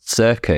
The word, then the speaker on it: Söka: But the reason why it's pronounced Söka is because the O has an umlaut, which kind of gives it that sound.